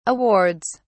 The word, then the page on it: awards: British English